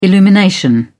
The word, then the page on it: illumination: British English